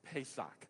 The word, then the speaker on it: Pesach: the Passover, Pesach. It's how it's pronounced.